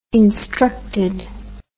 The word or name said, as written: instructed